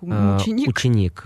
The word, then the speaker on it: Ученик: Это который, как правильно даже произносить, мученик? – Ученик. Фильм с Кириллом. Но он финально называется «Ученик».